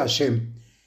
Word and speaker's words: Hashem: Y es eh, una meditación para todo el Salmo 119, no específica para esta letra, que son las letras Hei Shin Mem, que se pronuncia Hashem,